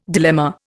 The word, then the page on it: dilemma: Indian